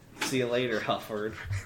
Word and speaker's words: HU4D: HU4D is said like Hufford. Whoops. Said pronounced like Hufford.